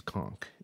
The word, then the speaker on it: conch: Different languages pronounce it differently. Different accents pronounce it differently. Perhaps Ben does pronounce it conch, but it is conch in American English.